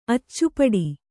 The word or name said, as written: ಅಚ್ಚುಪಡಿ